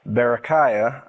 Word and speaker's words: Berechiah: son of Berechiah, I might be saying that wrong. – You're saying it right.